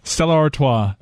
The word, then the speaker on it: Stella Artois: Stella Artois.